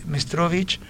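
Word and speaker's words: Meštrović: y eh, un, escultor, Iván Mestrovich, googleenlo, Iván Mestrovic se escribe,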